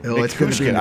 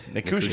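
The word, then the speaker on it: Nichushkin: Nikushkin. – I don't know why I can't say that. When I look at it, my brain freezes. Nikushkin. Yeah. It's just like it's spelled.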